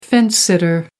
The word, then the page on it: fence sitter: American English